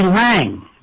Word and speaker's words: Laing: Lang. For those of you who are too young to remember Lang, his last name is spelled L-A-I-N-G.